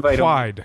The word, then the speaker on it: Whyd: It's pronounced Wide.